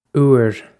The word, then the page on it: uair: Irish